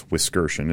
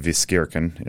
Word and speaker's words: Wiskirchen: What I usually say is Hi, I'm Jake Wiskirchen. Uh, that's how you pronounce the last name. It's Wiskirchen. It's German, um, originally pronounced Wiskirchen in the in the German ap- apparently.